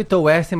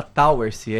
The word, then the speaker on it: Towersey: Se escreve Towessey, mas se fala Towersey, em inglês.